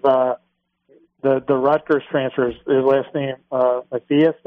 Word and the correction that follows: Mathis: did I pronounce it right? – Montez Mathis.